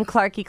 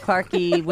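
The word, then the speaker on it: Clarkie: I'm Clarky Clark, Clarky with an I-E, Clarky Clark.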